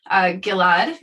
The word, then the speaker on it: Gilad: I actually came to know Gilad Rosinger, and that's a hard G, harder for Americans to pronounce.